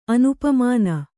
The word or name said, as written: ಅನುಪಮಾನ